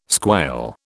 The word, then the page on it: squail: British English